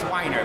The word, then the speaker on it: Weiner: It, it's spelled like hot dog, but pronounced like crybaby. It's Weiner, but W E I N E R.